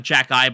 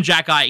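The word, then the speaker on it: Xhekaj: It doesn't look like Jacki, but I'm pretty sure that's how they say it. – Yeah. – It starts with an X, and it's like X-H. Something, it's insane, but they call him Jacki.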